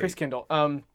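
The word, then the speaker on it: Christkindl: I was thinking that how, like, that when we went to the Christkindle, I always say it wrong. – Right? I think you're saying it right. – Christkindle.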